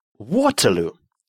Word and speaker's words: Waterloo: Waterloo. Waterloo. Небольшая деревня под Брюсселем. В песне произносится на английский манер.